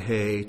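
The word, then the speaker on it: hay (ה): And he took Abram's name and added a hay to that, Aleph, Beit, Hay, Resh, Mem. You see? Now the letter hay is pronounced like the letter H. It's just an outbreathing.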